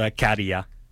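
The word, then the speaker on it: Käärijä: The artist is called Karia. – Garia.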